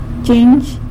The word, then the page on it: chinzh: sanlucasquiavini